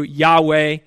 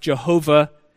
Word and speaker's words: Yahweh: his name which we pronounce from the Hebrew Yahweh. Formerly, it was oftentimes pronounced Jehovah.